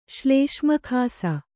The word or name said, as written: ಶ್ಲೇಷ್ಮಖಾಸ